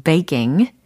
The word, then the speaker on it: baking: 베이킹이 아니라 베이 k 베이 g 베이 k 베이킹 하고 계십니까? 바이킹이 아니에요. 베이킹, 베이킹. 잘하셨어요. baking.